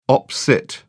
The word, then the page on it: op cit: British English